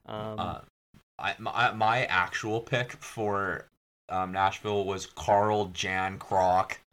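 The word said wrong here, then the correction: Calle Järnkrok: Cali Yarncroak.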